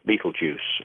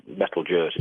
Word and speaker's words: Betelgeuse: Now, if you take, for example, there's a star called Betelgeuse, or Betelgeuse, sometimes pronounced, which is in the constellation of Orion, and you can see this on any clear night of the year.